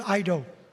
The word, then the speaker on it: eido: It's the Greek word eido. It's a silent E. E-I-D-O, eido.